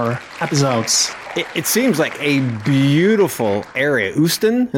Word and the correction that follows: Ostend: is, am I saying that right? Usten? – Ostend, Ostend, Ostend. Yeah. Okay, Ostend.